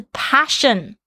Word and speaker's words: passion: 哦，我就那时候才想说，诶，我在讲我的热情啊，my passion，就他一直在讲说，不是 passion，是 passion。哦，就因为我一个字的那个 a 的发音错了，就不是热情。